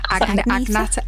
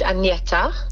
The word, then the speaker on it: Agneta: Agnata.